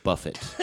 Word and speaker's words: Buffett: By the way, it's pronounced Buffett.